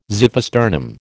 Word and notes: xiphisternum: American English

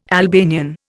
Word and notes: Albanian: Indian

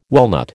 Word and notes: walnut: American English

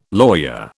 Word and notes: lawyer: British English

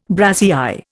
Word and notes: brassiÊre: Indian

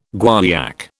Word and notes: guaiac: British English

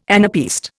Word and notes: anapaest: Indian

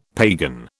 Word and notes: pagan: British English

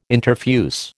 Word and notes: interfuse: American English

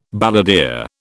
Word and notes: balladeer: British English

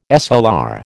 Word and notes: SLR: American English

